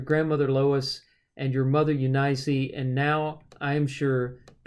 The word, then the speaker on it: Eunice: And it has Eunice as a three-syllable word with the accent on the middle syllable, a long I and a long E.